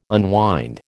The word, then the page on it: unwind: American English